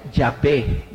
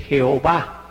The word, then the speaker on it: Yahvé: El sagrado tetragrama divino que se pronuncia Yahvé, bendito sea. En una mala traducción, a partir de la Edad Media, se dice Jehová, así dicen los testigos de Jehová,